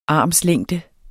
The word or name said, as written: armslængde